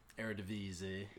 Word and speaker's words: Eredivisie: His team of the season in the Dutch league, the Eredivisie, how do you say that? – Eredivisie.